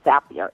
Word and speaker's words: Zapier: So I found this really cool tool that I absolutely love, and it's called, I think it's basically pronounced Zapier.